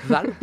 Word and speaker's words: Hval: Alors, ça s'écrit H-V-A-L, donc H-V-A-L. Je sais pas. – À cheval. On va dire val.